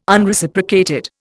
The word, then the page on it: Unreciprocated: Indian